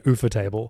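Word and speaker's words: Ufotable: Yeah, you know, I've heard it's pronounced like UFO table.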